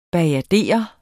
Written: BAJADERER

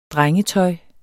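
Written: drengetøj

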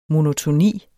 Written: monotoni

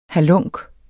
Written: halunk

